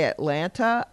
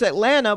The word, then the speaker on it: Atlanta: I have to, when I'm talking to someone and I say Atlanta, I make sure to say the last T, even though that is not how it is pronounced in the city.